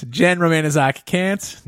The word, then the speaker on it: Jenn Romaniszak Kant: So who is here with us is Jenn Romaniszak Kant. I said it wrong. It's Jenn Romaniszak Kant, not like the philosopher.